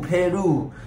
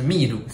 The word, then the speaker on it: 秘鲁: 然后就有个女生跟我说佩鲁，佩鲁。然后他说哈，佩鲁是哪里？然后后来才知道呢，是秘鲁，是 P E R U，佩鲁这样。